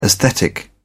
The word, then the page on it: aesthetic: English; British English